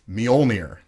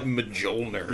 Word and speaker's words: Mjolnir: The whole thing that we're going to be doing is who is worthy enough to wield Thor's hammer, Mjolnir. Did I say that right? – Yeah. – Okay, good, great. – We'll take it. – Yeah, I had to like... – You didn't call it like Mjolnir.